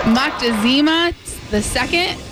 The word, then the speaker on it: Moctezuma: Moctezuma II? – Montezuma.